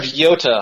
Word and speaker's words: Yota: Now you're the mayor of what is it, Iota? – Mayor of Yota. – Yota. – Long E. – Yota.